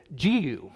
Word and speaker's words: Jehu: We might call it Jehu, but the official anglicized pronunciation of Hebrew is Jehu.